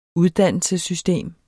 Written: uddannelsessystem

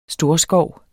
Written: storskov

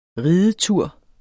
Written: ridetur

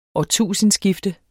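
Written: ÅRTUSINDESKIFTE

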